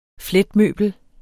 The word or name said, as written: fletmøbel